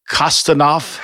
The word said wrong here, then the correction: Khusnutdinov: Marat Kuznudinov. – Kuznudinov. I missed a whole syllable in there.